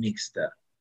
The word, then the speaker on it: Nixta: So the name came out from the short from nixtamalization. Mm-hmm. So we call it Nixta.